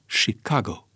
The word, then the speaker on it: Chicago: Este și orașul lui Al Capone și locul de naștere al grupării mafiote la Mano Nera, este și un mare port, dar pronunția corectă este Chicago. Chiar dacă se scrie cu CH, care se citește CI în engleză.